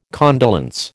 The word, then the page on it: condolence: American English